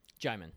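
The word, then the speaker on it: Djimon: We just talked about him, but Jaimin, you say it's pronounced Jaimin? – Jaimin.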